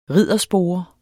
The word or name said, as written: RIDDERSPORE